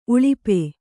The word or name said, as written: ಉಳುಪು